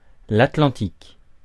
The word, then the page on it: Atlantique: France; [a.tlɑ̃.tik]